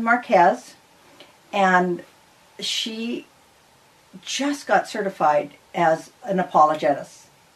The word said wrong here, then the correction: apologist: Did I say that correctly? – Apologist. – Apologist. Yeah.